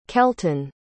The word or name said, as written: kelton